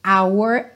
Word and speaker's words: hour: O H de hour é mudo, ok? Ao contrário da maioria dos Hs em inglês, que tem aquele som ha, ha, ha.